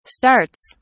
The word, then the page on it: starts: British English